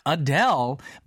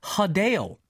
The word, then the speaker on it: Adele: Apparently, the correct way to say her name is not Adele, but we've been saying it all wrong this whole time, y'all. It's actually Hadale.